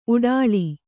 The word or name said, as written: ಊಟಳೆ